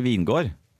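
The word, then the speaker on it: Vingegaard: Jo, men jeg tror ikke du uttaler gene så mye på dansk. Vingegård. Jonas Vingegård. Jonas Vingegård. Ja, så han heter egentlig Vingård. Eh, Jonas Vingård.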